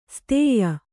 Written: ಸ್ತೇಯ

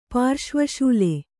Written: ಪಾರ್ಶ್ವಶೂಲೆ

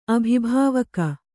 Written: ಅಭಿಭಾವಕ

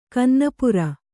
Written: ಕನ್ನಪುರ

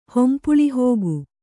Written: ಹೊಂಪುಳಿಹೋಗು

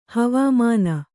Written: ಹವಾಮಾನ

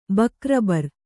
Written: ಬಕ್ರಬರ್

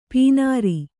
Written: ಪೇನಾರಿ

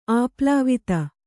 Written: ಆಪ್ಲಾವಿತ

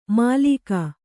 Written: ಮಾಲೀಕ

